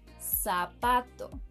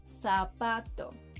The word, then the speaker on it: zapato: Zapato. Zapato.